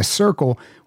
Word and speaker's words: Cyrkle: so I was happy to purchase that song by The Circle, which is spelled C-Y-R-K-L-E.